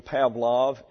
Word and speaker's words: Pavlov: That's his correct pronunciation, Pavlov in English, verbiage.